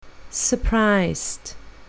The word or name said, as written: surprised